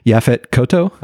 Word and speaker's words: Yaphet Kotto: Yafo, I can never pronounce it right. Yaphet Kotto.